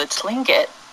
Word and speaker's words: Tlingit: Kushtaka are mythological shape-shifting creatures found in the stories of the Tlingit and the Tsimshian Indians of southeastern Alaska's temperate rainforest. – Notice I actually pronounced the names correctly the first time.